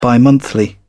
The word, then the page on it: bimonthly: British English